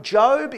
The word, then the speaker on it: Job: So if you have a Bible with you, or you can find it on your phone, Job is pronounced Job, but it's spelt Job, the book of Job.